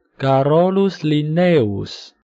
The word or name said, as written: Carolus Linnaeus